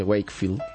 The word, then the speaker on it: Wakefield: ¿Cómo se pronuncia? Wakefield.